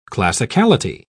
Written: classicality

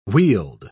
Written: wheeled

